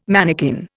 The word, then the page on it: manikin: Indian